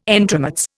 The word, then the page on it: entremets: Indian